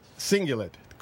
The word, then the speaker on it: cingulate: research has shown that uh, the uh, liberals have a larger anterior cingulate cortices. – Mm-hmm. – Cingulate, cingulate Cortices. Mm-hmm. Did I say that right? I believe you did.